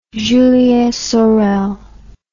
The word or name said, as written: julien sorel